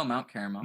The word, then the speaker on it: Carmel: Am I, am I mispronounced that? No. No, Mount Carmel. – Mount Carmel. Okay. Or Carmel. So depends.